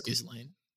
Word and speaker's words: Ghislaine: I think it's Ghislaine.